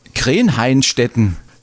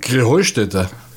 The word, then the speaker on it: Krähenheustätte: Ja, ihr saget immer Krähenhainstetten. Und dabei heißt es doch Krähenheustätte, oder wie ist es? – Krähenheustätte. – Ja, genau, und das ist die Betonung, ja, auf dem Heu.